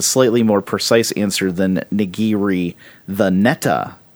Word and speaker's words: nigiri: It's three. It's nigiri.